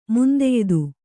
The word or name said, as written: ಮುಂದೆಯ್ದು